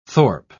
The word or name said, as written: thorp